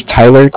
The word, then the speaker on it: Tyler: My name is Tyler, T-Y-L-E-R. – Mm-hmm. – And I'm calling with Cardiff. It's spelled C-A-R-D-I-F-F, as in Frank.